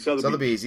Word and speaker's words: Sotheby's: Is it Sotheby's or Sotheby's? I don't know how you pronounce it. – Sotheby's, yeah.